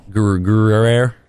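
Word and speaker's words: Gruyère: Gruyere.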